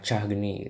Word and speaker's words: Cahgni: Eh, cara bacanya lebih ke arah dari akar katanya sendiri yaitu dari bahasa Jawa gabungan kata cah dan geni gitu jadi buat aku sih ngepronounce lebih ke arah Cahgni gitu.